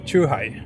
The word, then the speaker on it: chuhai: Hur uttalas det? Är det Shuhai? – Chuhai. – Chuhai, okej. Det låter roligare med Shuhai.